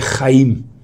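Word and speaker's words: Chaim (חיים): Vida em hebraico é Chaim. Em português é vida. Em hebraico é Haim, vidas.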